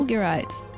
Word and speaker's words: fulgurites: So you are going today to tell us about lightning crystals, also known as, is it fulgurites? Did I say that right? – Fulgurites.